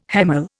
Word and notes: hemal: Indian

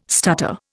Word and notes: stutter: Indian

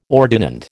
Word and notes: ordinand: American English

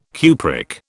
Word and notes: cupric: British English

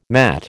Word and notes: matt: American English

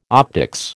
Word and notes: optics: American English

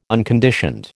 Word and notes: unconditioned: American English

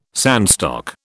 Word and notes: sandstock: British English

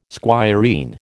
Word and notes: squireen: American English

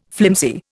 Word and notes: flimsy: Indian

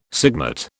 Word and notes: sigmate: British English

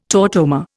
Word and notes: tautomer: Indian

